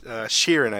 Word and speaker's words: Shiren: Shiren, I believe, is the correct pronunciation.